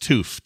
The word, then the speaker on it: Touf: He says, I knew of a family in the town I went to college in by the last name Toof, pronounced Toof, T-O-O-F, although pronounced T or spelled T-O-U-F.